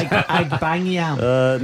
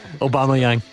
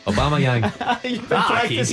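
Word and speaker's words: Aubameyang: Pronounce it again for us. – Agbayam. – No. Obamayang. – Obamayang.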